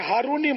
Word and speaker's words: ہارونی: معلوم یہ کرنا ہے کہ اس کا اصل تلفظ ہارونی ہے یا ہرونی. – تو یہ ہارونی مشہور ہے.